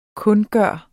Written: kundgøre

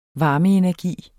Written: varmeenergi

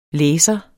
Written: læser

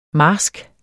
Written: marsk

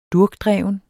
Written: durkdreven

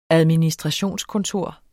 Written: administrationskontor

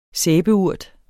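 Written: sæbeurt

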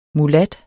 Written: MULAT

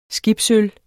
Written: skibsøl

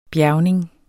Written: bjergning

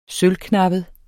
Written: sølvknappet

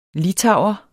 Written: litauer

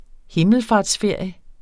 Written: himmelfartsferie

